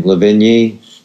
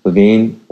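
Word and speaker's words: Lavigne: And why is Avril, I don't even know how to say her name, Lavigny? How do you say her name? – Levine.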